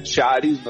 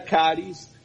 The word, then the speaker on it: CHARIS: Algunos lo pronuncian Charis, nos lo pronunciamos nosotros, ¿cierto?, en Latinoamérica, pero digamos que el término sería Caris, que son una, por las iniciales en inglés.